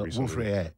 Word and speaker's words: Wolf-Rayet: Wolf-Rayet. R-A-Y-E-T. – Okay. – In French, you don't pronounce the trailing consonant. – Right, so Wolf-Rayet. Nice.